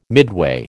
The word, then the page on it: midway: American English